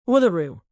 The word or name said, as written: uluru